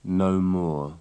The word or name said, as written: no more